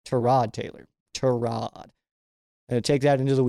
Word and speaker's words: Tyrod Taylor: And if you notice there, I did pronounce his name, Tyrod Taylor, because, well, that's how it's pronounced. And he's been saying this for years. He has been saying it's Tyrod for years. Like four teams ago, he was like, it's Tyrod Taylor.